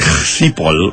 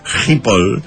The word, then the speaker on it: Schiphol: Bueno, yo llegué al aeropuerto de Schiphol, porque se dice así. – ¿Schiphol? – Más o menos, sí. Schiphol. Y ahí tomé mi primer café con leche.